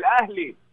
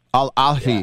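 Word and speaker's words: Ahly (الأهلي): It's not Al Ahly? – No, it's H, so Ahly. – Ahly. Al Ahly.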